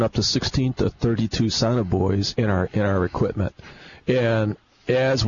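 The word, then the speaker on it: sonobuoy: You, first of all, drop the Sonoboys. – Mm-hmm. – Am I saying that right? Sonoboys. There we go.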